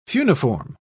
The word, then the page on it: funiform: British English